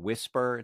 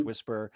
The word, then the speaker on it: WSPR: is the video really puts a spotlight on an idea called WSPR, Whisper. It's pronounced Whisper.